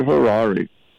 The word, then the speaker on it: Harari: And they aired it in a 60 Minutes interview with Dr. Harari. Rhymes with Curari, by the way. Dr. Harari is positioned by Anderson Cooper in his interview, 60 Minutes.